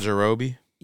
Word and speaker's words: Yajirobe: Yajirouji. I'm saying his name wrong, but cut off Yajirobe.